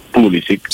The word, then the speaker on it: Pulisic: che ricordiamo si pronuncia Pulisic.